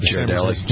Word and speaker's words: Ghirardelli: Ghirardelli.